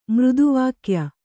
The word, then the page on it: ಮೃದುವಾಕ್ಯ: Kannada